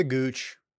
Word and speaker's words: Gooch: Taylor Gooch, Gotch, how do I say this guy's name? – It's the Gooch. It's T. Talor. Gooch.